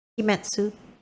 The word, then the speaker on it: Kimetsu: Yeah, Kimetsu.